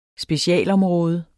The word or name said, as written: specialområde